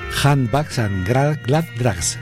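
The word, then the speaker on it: Handbags and Gladrags: La canción se llama Handbags and Grand Bags. Difícil de pronunciar. Ahora lo intento otra vez. Handbags and Glad Drags. Correcto.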